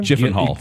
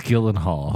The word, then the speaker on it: Gyllenhaal: With Jake, what's how do you pronounce his last name? – Gyllenhaal. – It's Gyllenhaal.